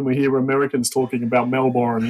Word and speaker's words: Melbourne: I always love the way Americans say Melbourne. Yeah. What's we just say Melbourne. – Melbourne? I can't say it that way. Melbourne. – Okay. It's like Melbourne, you know?